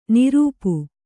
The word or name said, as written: ನೃಪ